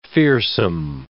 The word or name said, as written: fearsome